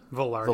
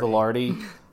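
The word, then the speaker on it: Vilardi: Velarde, Viardi. I don't know how to pronounce that one. – Velarde. – Velarde. Yeah. – That's not a double L. Yeah, it's just Velarde, I think.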